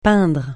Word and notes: peindre: francais

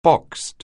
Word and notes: boxed: anglais

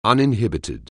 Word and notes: uninhibited: anglais

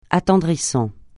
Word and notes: attendrissant: francais

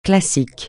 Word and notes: classique: francais

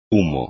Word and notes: humo: espagnol

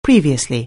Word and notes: previously: anglais

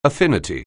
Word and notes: affinity: anglais

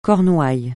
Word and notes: Cornouailles: francais

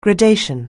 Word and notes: gradation: anglais

